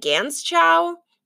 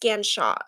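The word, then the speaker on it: Ganschow: I don't know how to spell my own last name, but it looks like Gans Chow, but it's pronounced Ganshaw. Okay?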